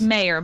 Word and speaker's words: Meyer: I guess I'm a Ms. and it's Mayor, but like these are just small details. – Do I always do the last name wrong on you? Is that what I? – It's okay. – Mayor, right? – I told you in advance. – Meyer, Mayor. – Yeah, like John Mayer.